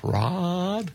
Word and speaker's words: Raad: R-A-A-D. What's the pronunciation of that? Raad? – Yeah, Raad.